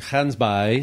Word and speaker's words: Gansbaai: and I was in Gansbaai. – Yes. Uh, Yes. My pronunciation good? Very good. Not bad.